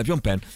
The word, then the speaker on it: Phnom Penh: Phnom Penh, Phnom Penh, dove migliaia di cambogiani sono stati torturati e rinchiusi dai Khmer Rouge.